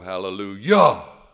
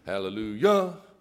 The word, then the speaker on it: hallelujah: "Hallelujah, hallelujah." So whenever I hear that, I cringe a little. So it's hallelujah, not hallelujah.